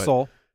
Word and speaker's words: Seoul: I get Tokyo, and I bought a how to pronounce it, but I think Seoul in South Korea. – Sure. – Seoul. I apologize on the pronunciation. – It's Seoul.